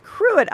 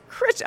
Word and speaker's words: Crich: It's actually spelled, and it looks like you would pronounce it Cruet Island, but it's pronounced Crich Island.